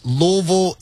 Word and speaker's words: Louisville: Y'all aren't saying Louisville or Louisville. It's Louisville, and I'm so happy and so thankful that y'all have said it correctly.